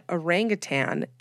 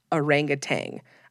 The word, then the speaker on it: orangutan: Next, I was way too old probably when I found out that orangutan is not spelled nor pronounced orangutang.